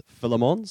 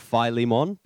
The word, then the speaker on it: Philemon: Now, I listened to a translation video which said that it's pronounced Philemon, so Phil Lee, with Lee being the emphasis mon, not Philemon,